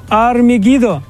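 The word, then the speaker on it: Армегидо (הר מגידו): Армагеддон на иврите произносится как Армегидо, гора Мегиддо.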